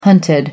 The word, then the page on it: hunted: American English